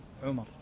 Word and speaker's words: عمر: عمر. – أي نعم. ترى الفرق بينها كتابة ونطقا، عمر بن حفص، وعمر هي التي فيها واو تكتب ولا تنطق إلا عند الوصل، عمرو بن العاص، وإذا سكت عمر.